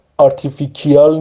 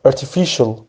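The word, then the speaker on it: artificial: ارتیفیشل، اینم ارتیفیکیال نیستش، بعضی سر کلاس اشتباه تلفظ میکنه، ارتیفیشل، مصنوعی، ارتیفیشل، چیزی که واقعی نیست.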